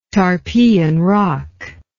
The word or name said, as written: tarpeian rock